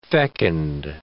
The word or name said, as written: fecund